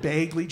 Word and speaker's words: Begley: How do you say the name of the uh, actor Ed Begley Jr.? Haig, right? Ed Bagley Jr., right? Sure.